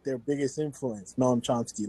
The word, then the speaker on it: Noam Chomsky: I saw it's a Noam Chomsky. I don't know if I'm pronouncing it right. – Noam Chomsky.